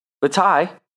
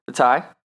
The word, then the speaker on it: Vaitai: They went out and they signed former Eagles offensive tackle, Vitae, or however you say it. Yeah, I think I said that right. Vitae. V-A-I-T-A-I.